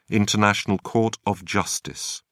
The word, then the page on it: international court of justice: British English